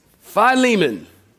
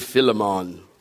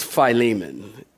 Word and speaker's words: Philemon: Philemon. Some of y'all pronounce it Philemon. One guy told me, he said, did you mean to say Philemon? I said, no, it's Philemon. It's Philemon.